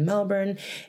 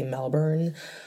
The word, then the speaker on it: Melbourne: I met her when I was backpacking in Melbourne for my Aussie listeners out there. I know you're gonna get mad if I say Melbourne. It's Melbourne, guys, say it right.